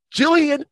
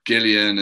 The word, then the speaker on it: Gillian: Can I just praise you for pronouncing her name right as well? Because I see so many people saying Gillian and, you know, all these weird pronunciations.